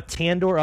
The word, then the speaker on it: tandoor: But they have a tandoor oven. Am I saying that right? – Yeah, And then they have a regular brick oven.